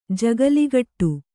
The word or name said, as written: ಜಗುಲಿಕಟ್ಟೆ